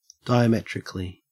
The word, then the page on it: diametrically: Australian English